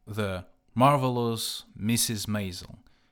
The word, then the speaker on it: The Marvelous Mrs. Maisel: А, сериал, который я постоянно упоминаю в данном выпуске, он произносится как The Marvelous Mrs. Maisel — надо как-то мне возвращаться к практике устного английского языка и письменного на самом деле, а то я уже совсем-совсем прям плох.